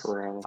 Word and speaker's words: Corral: I think I'm going to butcher his last name, but Matt Carroll from Ole Miss. – Corral. – I've seen Corral. – I've seen people. Huh, Corral. See, I knew I'd butcher it. – Like the restaurant.